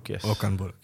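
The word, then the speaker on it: Okan Buruk: And the current manager, I hope I pronounced it right, Okanburuk? – Okanburuk, yes.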